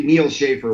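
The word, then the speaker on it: Neal Schaffer: Well, I've tried to be Neil Schaefer wherever there's a social network, and it's all spelled the same, NEALSCHAFFER.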